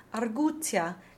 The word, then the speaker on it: arguzia: Then we have the Z's in astuzia, arguzia, and giudizio. Simone said that when a Z is followed by an I plus another vowel, the Z will be doubled, so phonetically T-T-S.